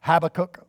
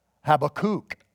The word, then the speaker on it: Habakkuk: Actually, his name is pronounced, I looked it up some time ago, and his name is actually pronounced, not, I used to always pronounce it Habakkuk or Habakkuk, but it's actually Habakkuk. The emphasis and the is on kook.